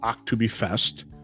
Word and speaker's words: Oktoberfest: The Oktoberfest, and the German pronunciation is Oktoberfest. The K is German, so you'll traditionally see it spelled O-K-T-O-B-E-R-E-F-E-S-T. The American version is Oktoberfest with a C.